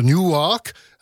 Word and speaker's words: Newark: Gwen Guthrie du New Jersey, originaire de la ville de Newark, à ne pas confondre avec New York, car ça se prononce presque pareil, ça s'écrit pas pareil.